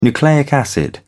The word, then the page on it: nucleic acid: British English